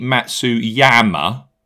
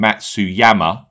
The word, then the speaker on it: Matsuyama: Now, any chance you could pronounce the Y in Matsuyama from now on?